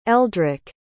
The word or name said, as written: eldrick